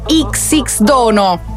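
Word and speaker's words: Xdono: formazione di Tiziano Ferro con Lazza, XX Dono. Ho sentito l'intervista, si pronuncia così.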